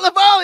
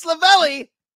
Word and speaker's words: Lavalle: It's not Lavalle. It's Lavalle. Oh, I know. God, I remembered it forever. – I remember it forever. It rhymes with belly.